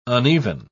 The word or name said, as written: uneven